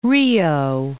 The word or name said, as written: Rio